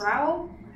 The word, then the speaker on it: Rowell: Rowell? – That is how you... – That's how you pronounce it.